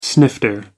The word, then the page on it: snifter: American English